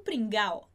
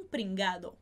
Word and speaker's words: pringado: Se a gente realmente pronunciar o D, ficaria este tio é um pringado. Perfeito? Como é que vai ficar a pronúncia dessa frase? Este tio é um pringao. Tá bom? Sem essa pronúncia do D.